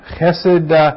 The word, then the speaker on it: chesed: It's spelled C-H-E-S-E-D, chesed, number 2617.